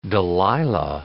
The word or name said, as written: Delilah